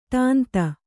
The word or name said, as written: ಟಾಂತ